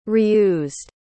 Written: reused

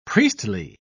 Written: priestly

